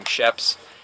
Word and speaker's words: Sheps: I use the username Sheps. And then same Sheps. Thing for spell that? – S-H-E-P-S.